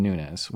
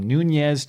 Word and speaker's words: Nunes: which I have heard his name pronounced everything from Nunez to Nunes to Nunes. So I'm going to go with Nunes because he doesn't have the little tilde above his N like the Spanish pronunciation.